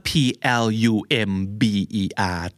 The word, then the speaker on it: plumber: P L U M B E R แต่ B ในที่นี้เป็น silent B ไม่ออกเสียงเพราะฉะนั้นคำนี้คือ plumber plumber ไม่ plumber นะครับ